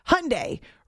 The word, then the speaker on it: Hyundai: When the Hyundai was first being sold in the U.S., they had an ad campaign that said something like, Hyundai rhymes with Sunday.